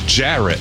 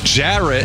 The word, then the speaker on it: Jarrett: Ferret does rhyme with Jarrett, but my name is Jarrett. Jarrett.